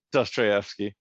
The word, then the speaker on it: Dostoevsky: They uh, Locke brings up these authors, you know, Hemingway and uh, Deskovsky. I, I don't remember how to say his name. Dost, Dostoevsky.